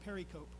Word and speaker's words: pericope: And you can always tell somebody who's a reader because they'll pronounce that pericope. And you know how I know? They pronounced it pericope until I heard pericope.